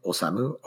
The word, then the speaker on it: Osamu: You can search my name, Osamu, O-S-A-M-U.